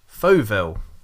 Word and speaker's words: Fauxville: I've got Mr. DK Pike on the podcast tonight, and we're here to promote his book, Fauville.